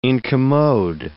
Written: incommode

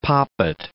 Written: poppet

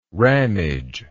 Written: ramage

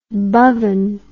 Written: Bhavan

